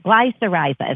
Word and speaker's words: glycyrrhiza: But the active ingredient in licorice is glycyrrhiza, is how you pronounce it.